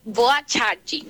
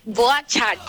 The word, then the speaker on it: boa tarde: Sí, en brasileño se dice hoy, boa charge. – ¡Ah! ¡Salúdeme en brasileño! Amiga, ¿cómo se dice? – Hola, Andresito. – Hola. – Sí, en brasileño se dice hoy, boa chati.